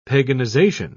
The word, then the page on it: paganization: British English